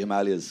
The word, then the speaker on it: Himalayas: One lives in the Himalayas and the other lives in Washington. – Himalayas. – What? – Himalayas. – What? – It is actually Himalayas, so don't look at me like that.